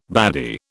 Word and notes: baddy: British English

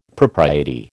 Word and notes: propriety: American English

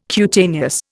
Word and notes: cutaneous: Indian

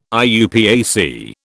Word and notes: IUPAC: British English

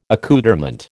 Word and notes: accoutrement: American English

